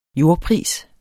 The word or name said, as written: jordpris